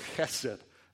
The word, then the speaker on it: chesed: It's the word chesed. Hebrew has like a lot of gutturals and pronunciation. I'm not just doing that for fun. Chesed. It would be spelled H-E-S-E-D. That's the way you typically see it spelled in English.